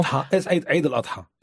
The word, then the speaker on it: Eid al-Adha: It's called the Festival of Hadaa. You've got a different pronunciation, I know. – Eid al-Adha.